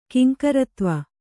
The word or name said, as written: ಕಿಂಕರತ್ವ